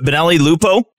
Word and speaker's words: Benelli Lupo: And I think June 9th, you put out a fantastic article called Benelli Lupo. Is that how you pronounce it? Benelli Lupo? – Yes, sir.